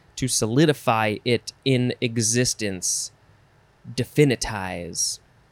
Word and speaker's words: definitize: The first word in this episode is definitize. Or you can emphasize a couple different syllables. Definitize or definitize. Definitize. D-E-F-I-N-I-T-I-Z-E.